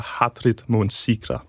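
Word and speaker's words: Hatrið mun sigra: Det er den islandske sang fra 2019 af bandet Hatari, som hedder Hatrit Mun Sigra, tror jeg, hvis jeg udtaler det rigtigt. – Det tror jeg, du gør.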